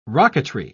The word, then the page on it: rocketry: British English